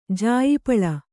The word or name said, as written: ಜಾಯಿಪಳ